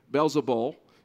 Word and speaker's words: Beelzebul: And you may have heard this pronounced, depending on the different translation, Beelzebul is the way that the ESV renders the pronunciation of that word.